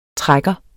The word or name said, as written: trækker